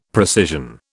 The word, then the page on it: precision: British English